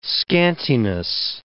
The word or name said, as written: scantiness